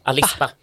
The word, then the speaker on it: Alice Bah: Jag tror man ska uttala det. Alice Bah, som ett ord.